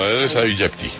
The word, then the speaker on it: Aedes aegypti: Lo que hay es que tener cuidado que no vaya y sea el Aedes aegypti. ¿Sí se dice así? – Sí, está perfecto, Aedes aegypti.